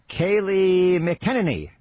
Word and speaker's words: Kayleigh McEnany: Kayleigh McEnany.